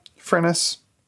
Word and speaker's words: Furness: Furness. She says rhymes with tennis.